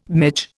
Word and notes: midge: Indian

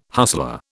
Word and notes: hustler: British English

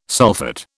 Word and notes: sulphate: British English

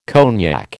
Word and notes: cognac: American English

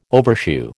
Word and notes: overshoe: American English

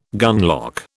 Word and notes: gunlock: British English

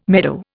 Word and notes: meadow: Indian

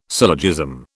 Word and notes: syllogism: British English

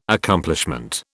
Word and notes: Accomplishment: British English

